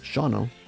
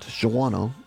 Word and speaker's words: Shawano: We are live here on location in beautiful Shawano, Wisconsin. If you're not sure where it's at, and by the way, it's pronounced Shawano, not Shawano, as many people say.